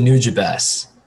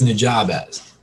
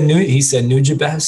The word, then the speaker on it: Nujabes: And he pronounced it Nujabes. And so I'm like, well, that's how you say it then. Cause I always read it as Nujabes. I don't know why.